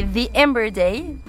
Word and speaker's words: The Amber Day: Alors en anglais, ça fait The Amber Day, mais c'est très bien The Amber Day. C'est très bien aussi.